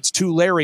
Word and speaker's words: Tulare: the Turali, it's Tulare. It's just outside of Fresno. I'm still working on the pronunciation.